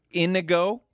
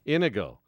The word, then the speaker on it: Inigo: E-U-S-T-A-C-E. Eustache. – Mm. – Eustache. – Yeah, all right. – Inigo, which is like indigo without the D. – Inigo. Oh, that's like the Princess Bride, right? – Don't know. – My name is... – Juan Pablo. – Inigo something.